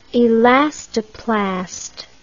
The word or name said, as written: elastoplast